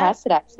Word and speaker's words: Precedex: Precedex.